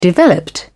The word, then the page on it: developed: British English